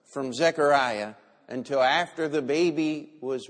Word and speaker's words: Zechariah: And so all of these things were going to happen, and Zechariah goes out.